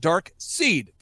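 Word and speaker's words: Darkseid: And in 1971, a comic was created called Dark Seed. Folks, look it up. Dark Seed. It's spelled kind of weird, but it's Dark Seed. Dark Seed, S-E-I-D. Dark Seed.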